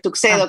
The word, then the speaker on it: Tuxedo: Y Tuxedo, en realidad se pronuncia así, pero es Tuxedo como se escribe, significa lo que sería un smoking en Estados Unidos o en Inglaterra.